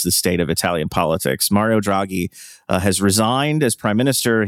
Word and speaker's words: Mario Draghi: Mario Draghi, yeah.